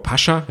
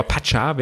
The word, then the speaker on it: Pascha: Ja, die haben ja so einen ganz, ganz feudalen VIP-Bereich in dem, ich sag ja nach wie vor Pascha, ja, so deutsch, aber Pascha wäre glaube ich, die richtige Aussprache.